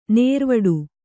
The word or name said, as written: ನಿರಿವಿಡಿ